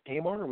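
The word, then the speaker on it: Aymar: Is it Imar or Imar? – You know, I think it's Imar. We've always said Imar, but everybody automatically says Imar.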